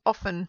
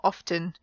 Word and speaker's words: often: And the question was, do you pronounce the T in this word? And it was a kind of trick question, really, because you can, but you don't have to. It's an option. So some people say often, some people say often, O-F-T-E-N, and both are okay.